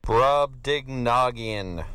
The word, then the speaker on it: Brobdingnagian: Brobdingnagian.